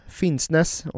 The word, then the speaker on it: Finnsnes: Men det märktes inte alls i det andra åket i Bardufoss, Finnsnes, är det rätt uttalat? – Ja, det är tillräckligt rätt för att man ska förstå var vi är på väg.